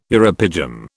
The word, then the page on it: uropygium: British English